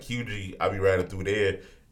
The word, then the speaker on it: Huger: And, like, yeah, it's Hugie, but when you read it, it's spelled Huger. H-U-G-E-R, I don't know. – That's that South shit. – Yeah, I don't know y'all. So yeah, the English dictionary. – There you go. – The R is silent.